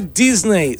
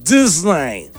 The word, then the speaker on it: Disney: No se dice Disney, se dice Disney.